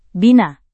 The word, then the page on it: bina: Indonesian